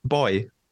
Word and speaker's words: buoy: David, I'm going to ask you, how do you pronounce the word that is spelled B-U-O-Y? – Buoy. – Okay. As a British person, we don't pronounce it like this. It probably should be pronounced like that. We pronounce it boy. Like, as in the exact same as the word B-O-Y, like girl and boy.